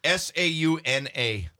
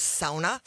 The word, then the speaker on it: sauna: Sauna. – Well, any good youper knows it is sauna. – Thank you. Not sauna.